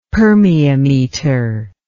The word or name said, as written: permeameter